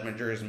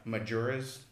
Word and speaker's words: Majora's: Majora's Mask? Majora's. – Okay. I've never heard it said that one. – How do you pronounce it? – Majora. – Majora's Mask. – There's an O.